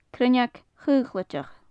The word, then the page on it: cruinneag chaochlaideach: gaelic